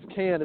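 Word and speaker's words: Kann: Oh, it's Alec Khan. Yeah, from Decatur. Yeah, Alec Cann. I've mispronounced it for a long time too.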